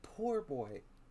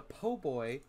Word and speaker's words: po' boy: Because it's not a fucking poor boy. It is a po' boy. It is pronounced and spelled po' boy.